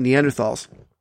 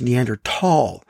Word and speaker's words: Neanderthal: I said Neanderthal because that's how I grew up pronouncing it. But we're not supposed to pronounce it that way anymore because the name corresponds to an actual place in Germany. And in the German language, TH doesn't make the F sound. So the correct pronunciation is Neanderthal.